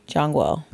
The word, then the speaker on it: Zhongwo: The Z-H is a J. – Zhongwo. – Zhongwo.